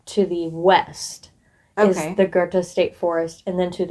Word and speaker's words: Goethe: That, I'm pretty sure that's exactly how you say it is Goethe.